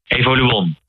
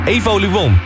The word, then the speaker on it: Evoluon: En die luidt, is het Evoluon of Evoluon? – Evoluon. – Evoluon. Oh. – Ja, klopt. Ja.